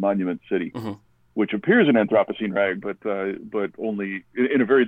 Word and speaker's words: Anthropocene Rag: Anthropocene Rag. Can you tell us a little bit about this one? – You didn't say it wrong. You said it right.